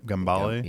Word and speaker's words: Gambale: First one I saw the day, Frank Gambale, right? Or is that how you say it? – Yep. – Gambale? – Yeah, Gambale.